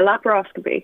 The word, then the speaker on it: laparoscopy: Yeah, laparoscopy.